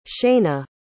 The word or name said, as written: shaina